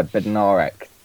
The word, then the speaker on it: Bednarek: Yeah, there's a few pronunciations I've seen lurking around. I think, Bednarek. Is the way.